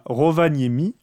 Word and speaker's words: Rovaniemi: mais ce que je trouve intéressant, c'est que le maire de Libourne, il s'est rendu à Rovaniemi, j'espère que je mhm.